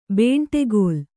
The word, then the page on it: ಬಂಡೆಕಲ್ಲು: baṇḍe kallu